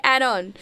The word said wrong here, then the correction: anon: Add-on? Is that how you say it? – Add-on. – Add-on. I don't know. Anyway. – Anon. – Yeah, anonymous. Anonymous.